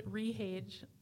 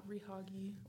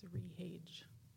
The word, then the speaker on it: Rhage: So my name is Rhage, like again and again and again, and then age. I used to tell my students that it rhymes with Nick Cage. It's not Rehoggy or Rehage, it's Rhage.